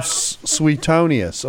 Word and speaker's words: Suetonius: And then the second example comes from Suetonius, or as mm-hmm. W.A. Criswell used to pronounce it, Suetonius.